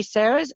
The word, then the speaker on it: Sara: I've met many Sarahs, and it really suits them, but I then said, I haven't changed my name. I've just changed the pronunciation to Sara.